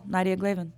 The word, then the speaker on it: Nadiia Glavin: Just Google Nadiia Glavin. Two I's in my name. That's because in my language, it's spelled in Cyrillic. So when you translate to English, it creates that extra I.